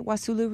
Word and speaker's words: Wassoulou: So you were in Paris now, but your family is from the Wassoulou, am I saying that right? Wassoulou region of Mali? – Exactly, yeah.